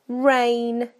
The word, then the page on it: rain: British English